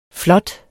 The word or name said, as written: flot